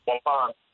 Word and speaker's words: Waupun: Am I saying that? Waupun? How would I say that? – Waupun. – All right. – Waupun, Wisconsin.